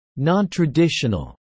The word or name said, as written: nontraditional